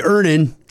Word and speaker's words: Earnin: Earnin. E-A-R-N-I-N is pronounced Earnin.